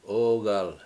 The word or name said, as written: OOgal